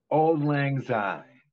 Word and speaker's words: Auld Lang Syne: Auld Lang Syne.